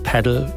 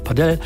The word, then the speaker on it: Padel: Korrekt, die meisten sagen Paddle, manche sagen aber auch Padel, weil es wohl iberische Wurzeln habe.